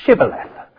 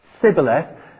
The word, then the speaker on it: Shibboleth: And if he replied, "No," they all said, "All right then, say Shibboleth." And if he said Sibboleth, because he couldn't pronounce the word correctly, they seized him and killed him at the fords of the Jordan.